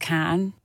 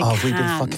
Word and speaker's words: Cannes: You're not pronounced the S, it's just can. Like, apparently you don't say Cannes.